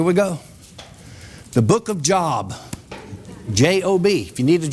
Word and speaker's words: Job: Ain't it J-O-B? How you say it? J-O-B? How do you pronounce it? – Job. – Job. Oh, Job.